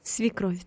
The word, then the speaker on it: свекровь: особенно сверхов, или правильно сказал, мама-супруга. – Свекровь, так.